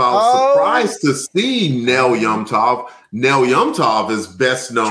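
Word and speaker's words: Nel Yomtov: Actually, I'm just saying that to drive comic foil nuts because I think he mentioned in the chat that it's probably pronounced Nell Yamtov, and I've been reading it wrong for 18 episodes, and I'm not going to stop now.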